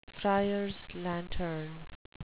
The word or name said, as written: friars lantern